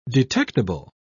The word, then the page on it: detectible: British English